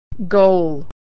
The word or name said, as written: goal